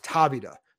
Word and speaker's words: Tavita: Nathaniel Roberts, you mentioned Tavita, and it is pronounced Tavita, because I even asked him, possibly taking over for Bieniemy if he becomes a head coach.